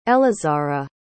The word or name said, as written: elizara